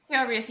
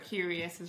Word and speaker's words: Qrious: So today I'd love to welcome the Chief Operating Officer at a business consultancy called Qrious. Hope I pronounced that correctly. – Qrious, you have, yes. We get many versions of it, but Qrious is right.